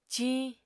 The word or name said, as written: ciiñ